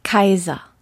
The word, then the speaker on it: Caesar: Gaius Julius Caesar. – 이야. – 카이저? – 카이저.